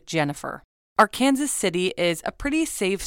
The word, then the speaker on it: Arkansas: Jody Sanderholm was born on September 26, 1987 in Arkansas City, Kansas, which is the correct pronunciation. – Yeah, it's Arkansas, apparently. That's how everybody says it there.